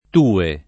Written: tue

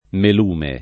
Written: melume

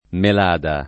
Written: Melada

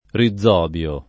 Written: rizobio

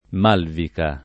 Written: Malvica

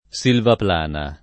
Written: Silvaplana

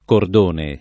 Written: cordone

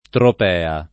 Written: Tropea

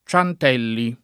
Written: Ciantelli

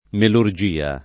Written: melurgia

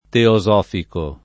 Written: teosofico

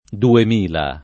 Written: duemila

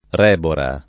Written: Rebora

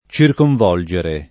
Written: circonvolgere

